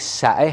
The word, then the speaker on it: سعه: سعه که تلفظ درستش در عربی سعه هست، یعنی فراخی، گشادگی، وسعت.